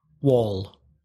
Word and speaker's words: wall: See, a lot of students don't understand my pronunciation of that word. I say wall. Wall. Say it again. – Wall. – Yeah, your vowel sounds a bit wider.